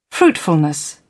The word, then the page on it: fruitfulness: British English